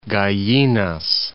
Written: Gallinas, Point

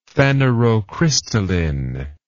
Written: phanerocrystalline

